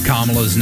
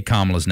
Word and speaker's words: Kamala: Kamala's name, even though on the teleprompters they had spelled it out phonetically just to make sure every speaker got it right. Kama, yep. Dash law.